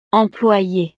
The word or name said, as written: employe